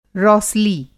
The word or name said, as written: रौसली